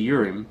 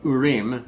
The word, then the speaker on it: Urim: So that's the casting, the judgment of the Urim, or Urim, as they would be pronounced.